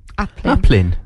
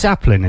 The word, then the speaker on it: Aplin: How do you say it? – I don't know. – How do you spell it? – A-P-L-I-N. – Applin. – It's Applin, is it? – Yeah, that's.